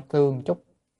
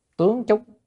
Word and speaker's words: tướng chúc: Không phải tương chúc, tướng chúc. Chữ này đọc là tướng mới đúng, đọc tương không đúng.